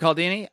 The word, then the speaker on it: Cialdini: And from that book, I learned about Robert uh, I think you call him Cialdini or Cialdini. Um, uh, yeah, Robert Cialdini.